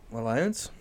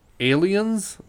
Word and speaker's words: Allianz: It's Allianz. – Alliance? – That's what I thought. But it's actually like a long A, so it's Aliens Field.